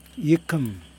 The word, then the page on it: jikim: Yokoim